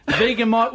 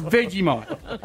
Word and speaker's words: Vegemite: And even if you give me Promite, Marmite, Mighty Mite, Osmite, Amigamite, Vegamite, whatever mite it is, number one is Vegemite. – I think Vegamite's just Vegemite pronounced wrong.